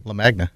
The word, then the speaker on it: Lamagna: Lamagna.